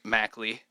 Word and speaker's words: Mackley: There will be many more sources in the near future to find things Mackley.